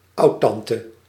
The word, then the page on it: oudtante: /ˈɑu̯tɑntə/